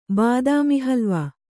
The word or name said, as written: ಬಾದಾಮಿಹಲ್ವ